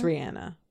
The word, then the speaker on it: Rihanna: This is the correct pronunciation. – Rihanna? – It's Rihanna.